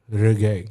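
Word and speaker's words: Regé: Isn't his name Reggae? – I don't know. I thought it's a soft J. – No, it's the N. That's A. – Reggae. Yeah. I said Roger. – No, it's like the music.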